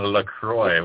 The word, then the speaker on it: LaCroix: And LaCroix. What, is that like bubbly water? – Thank you for saying it right, by the way.